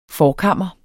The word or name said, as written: forkammer